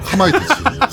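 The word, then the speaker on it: カマイタチ: 키마이 타치. 가마이 됐치아 카마이 타치. 카마이 타치. 이걸 내가 왜 키라고 읽었을까 카마이 타치.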